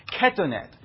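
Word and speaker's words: Ketonet (כתונת): Ketonet is the way that this word is pronounced.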